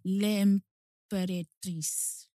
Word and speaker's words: L'Imperatrice: L'Imperatrice. L-I-M-P-E-R-A-T-R-I-C-E.